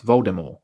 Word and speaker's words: Voldemort: Remember that Voldemort, the T is silent. So it's actually pronounced Voldemort.